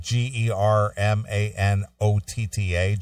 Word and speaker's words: Germanotta: G E R M A N O T T A, Germanotta.